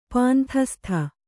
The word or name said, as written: ಪಾಂಥಸ್ತ